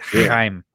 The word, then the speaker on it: Chaim: I don't even know how to pronounce the I think it's Chaim. – Chaim Gazzoli. – That's the proper way to say it. Chaim. Yeah.